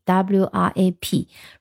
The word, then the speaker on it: wrap: wrap up，那 wrap，呃，不是那个饶舌。呃，歌的那个那个叫 rap，嗯，这种 r a p 啊，读音是一模一样，但是它前面还有个 w w r a p wrap up。